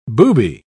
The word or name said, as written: booby